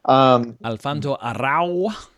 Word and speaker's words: Alfonso Arau: He told Alfonso Arau, I don't know how to say his name. – Alfonso Arau.